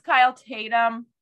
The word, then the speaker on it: Kyle Tatum: So this is Kyle Tatum. Is this how we say it, Kyle? – Yeah.